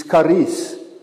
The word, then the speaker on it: charis: When you pronounce it correctly, it's charis.